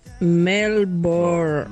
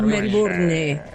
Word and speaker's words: Melbourne: Es Melbourne. Sin la E. No Melbourne. – Correcto. – Melbourne. – Es como Ramona Sherburne.